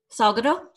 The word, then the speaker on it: Salgado: Is it S-A-L-G-A-D-O? – Yeah. – Salgado? – Salgado.